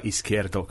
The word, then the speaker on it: Izquierdo: No, I did it wrong actually, because it's like Izquierdo, because it's a Latin American thing, so you pronounce the Z.